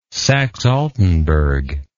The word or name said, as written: saxealtenburg